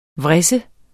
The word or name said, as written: vris